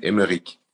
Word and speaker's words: Emeric: I know, originally it's a German name, but I have the French spelling, E-M-E-R-I-C, and you pronounce it Emeric. – Emeric, my gosh, now my French is like, oh, géante, okay.